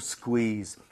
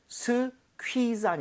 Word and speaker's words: squeeze: 그래서 오늘 뭐 squeeze, 뭐 pop, drain 다 나왔네요. 그렇죠? 오케이. – 와우. – 그리고 발음 주의하셔야 됩니다. 스퀴가 아니라 쿠이로 다 나눠서 하셔야 돼요. 스퀴즈 아니라 squeeze. squeeze.